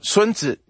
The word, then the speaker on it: Sun Tzu: So what this would look like is, again, Trump, it's like Sun Tzu, right? Or Sun Tzu, as it's pronounced in Chinese, the art of war.